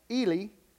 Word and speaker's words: Heli: We know from Luke 3:23 that Mary was the daughter of a man named Eli, spelled H-E-L-I, but I think pronounced Eli.